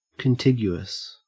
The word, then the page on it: contiguous: Australian English